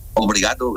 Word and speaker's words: obrigado: ¿Cómo, obligado, sí, ¿cómo se dice? Obligado es gracia, ¿no? – Obrigado, gracias. – Señora.